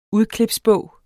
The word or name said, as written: udklipsbog